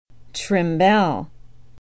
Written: trimbelle

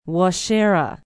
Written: waushara

